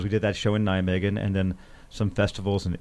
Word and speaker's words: Nijmegen: Well, speaking about not awful records, we got a comment from somebody overseas who said that the Baseball Project show in Nijmegen, I'm not sure if I'm pronouncing that right. – Nijmegen.